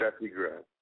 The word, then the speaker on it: Steffi Graf: Steffi Graf.